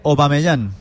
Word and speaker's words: Aubameyang: Correcta es Aubameyang.